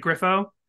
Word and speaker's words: Griffo: Griffo? – That's right.